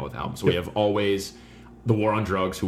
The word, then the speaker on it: Alvvays: We have Alvvays or Alvvays. – With two V's. I think they just pronounce it Alvvays.